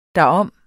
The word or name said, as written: derom